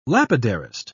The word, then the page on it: lapidarist: British English